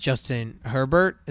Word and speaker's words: Justin Herbert: But I can easily see them losing to Stanford, losing to Oregon, because Oregon has Justin Herbert. Is that how you say his name? – Yes.